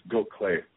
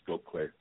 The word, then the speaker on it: Gokhale: So the – yeah, it's Gokhale. – Gokhale. – It's not an intuitive pronunciation, but yeah, it's pronounced Gokhale.